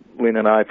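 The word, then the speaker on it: Lindenow: Am I saying it right? Lindenau or Lindenau? – Lindenau.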